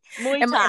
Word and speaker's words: Muay Thai: You signed up to be like a Muay Thai fighter. I don't know if I'm saying this correctly. – Muay Thai.